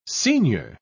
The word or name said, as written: seignior